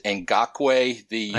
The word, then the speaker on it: Ngakoue: I think you pronounce it Ngakoue. – I think that's right.